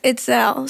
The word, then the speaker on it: Itzel: So my name is Itzel. So it's spelled I-T-Z-E-L.